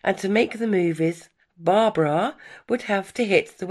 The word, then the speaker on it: Barbra: Winning a talent contest in a gay bar started her career, and disliking her name, she changed it from Barbara to Barbra, like bra.